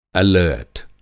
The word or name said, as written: एलर्ट